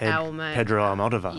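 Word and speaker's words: Almodóvar: Pedro Almodovar.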